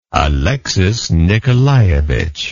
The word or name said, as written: alexis nikolayevich